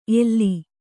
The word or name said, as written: ಎಲ್ಲಿ